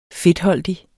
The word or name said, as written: fedtholdig